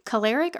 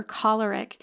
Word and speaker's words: choleric: It's pronounced choleric or choleric.